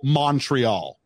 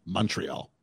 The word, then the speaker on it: Montreal: Or they'll go Montreal when a local would pronounce M-O-N like M-U-N, Montreal.